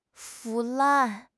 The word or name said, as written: 腐烂